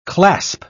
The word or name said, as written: clasp